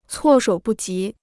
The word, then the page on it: 措手不及: cuò shǒu bù jí